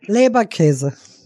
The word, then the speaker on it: Leberkäse: Leberkäse.